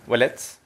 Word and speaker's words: Ouellet: My name is William Ouellet. It's like a W, basically, as if it was WW.